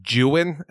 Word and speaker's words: Don Juan: in order for it to rhyme correctly, is supposed to be pronounced Jewin, and I was like, well.